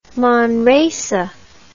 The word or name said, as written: Manresa